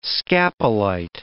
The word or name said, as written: scapolite